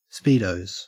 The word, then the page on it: speedos: Australian English